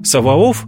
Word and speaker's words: Саваоф: Саваоф – греко-славянское произношение еврейского понятия «цеваот».